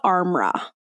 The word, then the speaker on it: Armra: I think it's called Armra. Okay. A R M R A, I think is how you say it or is how it's spelled.